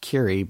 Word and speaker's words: Kure: We all would share this beach cottage down at the beach in Curie Beach, North Carolina, pronounced Curie, but like Madame Curie, but spelled K-U-R-E.